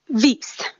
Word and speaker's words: vis: So, for example, the main word for rape in Rome, and the one that Ovid uses most, is vis, V-I-S. Sometimes it's pronounced vis.